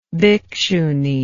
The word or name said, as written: bhikshuni